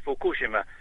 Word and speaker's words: Fukushima: Well, the book is on, we pronounce it Fukushima, but by my colleagues from Japan tell me it's Fukushima, the reactor there in Japan that exploded just in March of 2011.